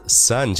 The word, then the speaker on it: son: 哎，那关于儿子 son 这个词，你可千万不要通过拼写去猜读音，不然的话你会读成 son a song。肯定都是不对的。